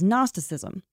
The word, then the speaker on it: Gnosticism: But one of these other branches, another really popular one that was always fighting with the Proto-Orthodox branch, was Gnosticism, spelled with a G-N, silent G like in gnat or gnarly.